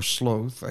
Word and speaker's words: sloth: Um, they are gluttony, pride, lust, greed, wrath, sloth, or sloth, I guess if you want to say the alternate pronunciation, and envy.